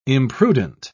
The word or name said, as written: imprudent